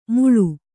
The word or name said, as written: ಮುಳು